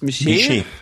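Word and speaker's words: Miche: Miché.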